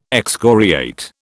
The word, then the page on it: excoriate: British English